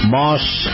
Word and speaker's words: Maus: Moss Acura. That's spelled M-A-U-S,